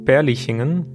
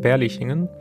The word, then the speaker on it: Berlichingen: Und es geht eben um das Schauspiel über den Ritter Götz von Berlichingen. Ich habe es gerade falsch ausgesprochen, Berlichingen.